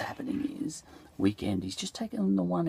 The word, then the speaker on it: Weeknd: So there's a rapper, for example, called Weeknd. You know, but it's pronounced weekend.